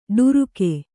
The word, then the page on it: ಡರಿಕೆ: ḍarike